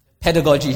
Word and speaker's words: pedagogy: Pedagogy.